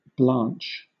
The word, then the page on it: blanch: British English